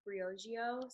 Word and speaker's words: Briogeo: and it's pronounced Briogeo.